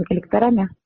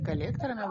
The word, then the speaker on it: коллекторами: Будете обсуждать по улицам с выездными коллекторами? – Правильно говорить коллекторами, во-первых.